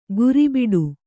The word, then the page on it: ಗುರಿಬಿಡು: guribiḍu; Kannada